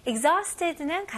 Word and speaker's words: exhausted: 오, 여기는 스펠링하고 다르게 발음이 정말 달라요. 여기서는 exhausted. 여기서는 약간 가벼운 G 발음에다가 Z로 넘어가요. Exhausted. 해볼게요. Exhausted. I'm exhausted. Exhausted. 강세는 두 번째 음절이에요.